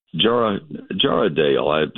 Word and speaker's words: Jarrahdale: Jarrahdale, I looked that one up and checked the pronunciation on it. J-A-R-R-A-H-D-A-L-E, Jarrahdale pumpkins are a beautiful steel blue-gray color.